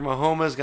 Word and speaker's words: Mahomes: Patrick Mahomes got his first Super Bowl win. – I love the way you say his last name. – That's how you pronounce it.